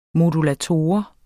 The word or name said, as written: modulator